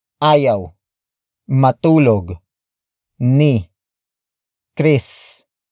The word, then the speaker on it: matulog: Ayaw is spelled as A-Y-A-W. It means to refuse. The next word is matulog. Matulog. Matulog. Matulog is spelled as M-A-T-U-L-O-G.